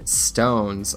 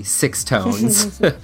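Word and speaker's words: SixTONES: JUMP, Kiss My Foot 2, and Stones, or, well, phonetically, Six Tones.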